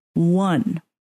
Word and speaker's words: Juan: Like, we've been saying Juan our whole life. But we looked it up and we had to like look it up on like YouTube. And it's one. That's how you pronounce like I won.